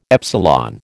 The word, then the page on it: epsilon: American English